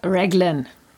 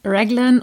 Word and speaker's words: Raglan: Also die richtige englische Aussprache wäre Lord Raglan. Demzufolge heißt es auch englischsprachig richtig Raglan oder Raglan Sleeves oder Raglan Construction oder wie auch immer.